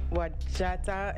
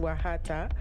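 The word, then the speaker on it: Wajatta: And so I had some facts to share about the group, the duo Wajata, and I learned today that it's actually pronounced Wahata. So it's the duo formed by Reggie Watts and John Tejada. And the name of the group comes from the combination of their songs, and it's pronounced Wahata, like the J is pronounced as an H.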